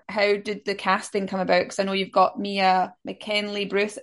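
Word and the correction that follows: Mia McKenna-Bruce: Have I said her name wrong? McKenna Bruce. – There we go. – Mia McKenna Bruce.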